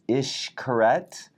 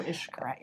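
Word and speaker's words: Xcaret: And Wendy and Stacy both corrected me on my pronunciation. It's Ishkaret. – Oh, Ishkaret, yes.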